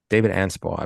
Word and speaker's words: Anspaugh: David Anspaugh? – David Anspaugh, I believe so, yes.